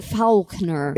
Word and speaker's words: Faulkner: Is it Faulkner or is it Faulkner? – It's Faulkner.